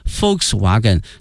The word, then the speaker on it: Volkswagen: 这一段里面我们将学到很多、啊、大家非常熟悉的一些车的品牌，首先看一下大众、啊、 VW，它的全称呢是 Volkswagen，因为在德语里面这个 Volks、啊、注意一下这个 V、啊、它的发音呢是 F 音、啊、 Volkswagen